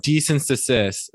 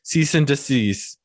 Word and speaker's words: cease and desist: Why is it anytime that anyone ever says cease and desist, they always get it wrong? Decent desist. Like, it's not that hard, in my opinion. – I said it properly. – Yeah, I'm aware. I'm just saying in general, like, people will get that shit wrong. Cease and desist. Decease and desist.